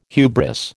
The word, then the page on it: hubris: American English